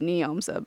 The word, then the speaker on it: Njomza: It's pronounced Neomza,